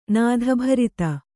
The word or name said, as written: ನಾಧಭರಿತ